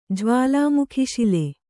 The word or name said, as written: ಜ್ವಾಲಾಮುಖಿಶಿಲೆ